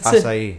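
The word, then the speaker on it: açaí: Now, I always mispronounce this. Acai? Is that how you pronounce acai. – It? Acai. Acai, that's right.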